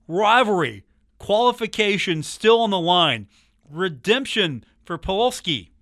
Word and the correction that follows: Pulisic: Did I say that right? Pulisic? – Pulisic. – Pulisic, okay. Thank you.